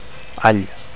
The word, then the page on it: all: Catalan